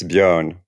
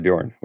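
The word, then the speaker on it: Björn: The thing is, it's a Swedish letter, so it's Bjorn, like learn.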